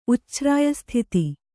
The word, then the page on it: ಉಚ್ಛ್ರಾಯಸ್ಥಿತಿ: Kannada